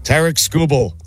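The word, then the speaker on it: Tarik Skubal: Tarek Skubal. Did I say it right? – Yep.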